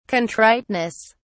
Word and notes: contriteness: British English

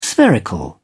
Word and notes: spherical: British English

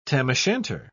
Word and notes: tam-o'-shanter: British English

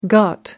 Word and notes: ghat: American English

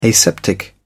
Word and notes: aseptic: British English

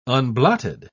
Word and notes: unblotted: British English

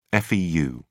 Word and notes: feu: British English

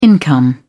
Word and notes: income: British English

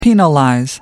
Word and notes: penalize: American English

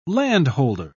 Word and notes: landholder: British English

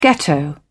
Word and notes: ghetto: British English

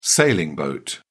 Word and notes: sailing boat: British English

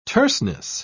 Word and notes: terseness: British English

